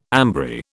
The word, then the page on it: ambry: British English